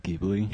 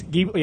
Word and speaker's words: Ghibli: Ghibli. – It's Ghibli. Yeah, okay, I was going to say Ghibli's correct, but Ghibli.